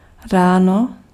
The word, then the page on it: ráno: /ˈraː.nɔ/